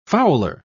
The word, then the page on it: fowler: British English